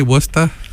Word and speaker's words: Worcester: And Nick did a radio podcast for Socialist Alternative out at Worcester State University.